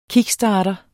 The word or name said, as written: kickstarter